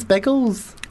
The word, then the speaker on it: Biggles: I was like, I'm looking for Big Les. – Wow. Yeah. – They're like, who's Les? Who's Big Les? Big Bird. There's Big Bird, but there's no Big Les. Obviously mispronounced. Oh, he means Biggles.